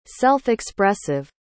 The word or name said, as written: self-expressive